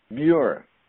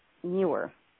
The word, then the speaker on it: Muir: Muir. – Muir.